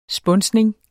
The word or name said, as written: spunsning